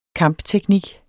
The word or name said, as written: kampteknik